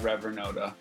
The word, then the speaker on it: Revernota: Is that how you pronounce it? – Yeah. – I want to make sure. – I pronounce it Reverend Noda. – Reverend Noda. Okay, yeah. That's important for me to know. – Okay, yeah, cool. Yeah, that's how I pronounce Reverend it. Noda. It's a made up word.